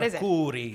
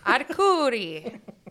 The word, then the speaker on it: Arcuri: Alex, is that our Curie for 500? I'm going to let you roll your R's on this one. What Arcuri. – Is it? Arcuri.